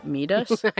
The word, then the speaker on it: Métis: Métis? – I know. Who knew we had been pronouncing Métis wrong this whole time? – Oh, God, how embarrassing.